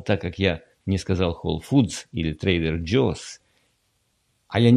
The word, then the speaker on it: Whole Foods: Если будете покупать в Whole Foods, меня поправляли, Whole Foods надо говорить, извините, так называется магазин. И мне сказали, что из-за этого, как я вообще могу преподавать английский, если я не произношу букву S в названии магазина Whole Foods.